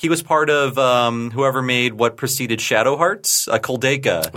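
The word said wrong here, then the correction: Koudelka: That was his company, Kodelka. Sorry, I always get the name wrong.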